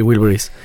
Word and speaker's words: Wilburys: Sí, Wilburys.